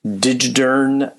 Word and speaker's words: Digidern: One of which joins us today, Justin Sisley from Digidern Bookkeeping.